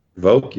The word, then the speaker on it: Vovk: Vovk, you got it.